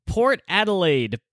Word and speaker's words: Port Adelaide: Port Adelaide, the Power.